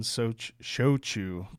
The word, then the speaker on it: Shochu: So we're joined today by Taka Amano, the co-founder and CEO of the American Shochu Company.